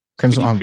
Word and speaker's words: Kremsmünster: I mean, if I look up your company, it says in Monroe, North Carolina, but then there's Kremsmünster. – Yeah, that's the. – Did I pronounce that right? Did I pronounce that right? – Kremsmünster. Pretty close. Yeah.